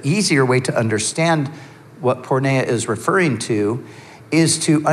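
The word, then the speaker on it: porneia (πορνεία): So sexual immorality is the translation of a Greek word that is pronounced porneia.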